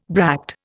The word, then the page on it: bract: Indian